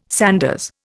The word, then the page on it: sanders: Indian